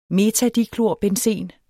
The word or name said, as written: metadiklorbenzen